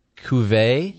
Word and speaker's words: Cuvée: Am I saying it correctly? The Owner's Reserve Cuvée? – Yes.